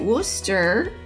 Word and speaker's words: Worcester: So you can tell me if I got it right. Worcester. – Yeah. – Yes. Okay.